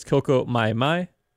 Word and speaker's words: Coco Mai Mai: And before that, you heard Jack Costanzo and his Afro-Cuban band with, and I mispronounced this earlier, but it's Coco Mai Mai, off of the record Coco Mai Mai, 1955 to 1962, Spanish Harlem Dance Floor Fillers.